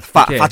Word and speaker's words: 发: The 发 or is the same pronunciation as prosperous. – The 发. – Yeah, fa choy.